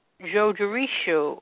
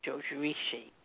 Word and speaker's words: Zojirushi: Jojirisho, let me just spell it, Z-O-J-I-R-U-S-H-I, Jojirisho.